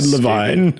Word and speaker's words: Levine: Yeah, no, Ted Levine.